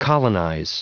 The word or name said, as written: colonize